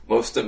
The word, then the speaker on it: Mostert: How do you pronounce his name? – Mustard. – Mustard, did you say? – Yes. – And, yeah, I mean, Breida averaging five yards a carry last game. Mostert, 6.6.